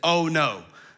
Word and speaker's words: Ono: And yes, that literally is Ono. I thought it had to be something super spiritual to that. No, that is literally how you pronounce it. Ono.